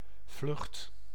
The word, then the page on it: vlucht: [vlʏχt]